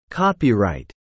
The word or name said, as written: copyright